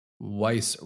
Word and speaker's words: Weis: Weis rhymes with nice, but it's spelled differently.